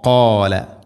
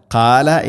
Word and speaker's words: قال: കോ കോല കാല എന്ന് പറയരുത്, കാല അല്ല കോ.